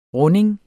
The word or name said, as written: runding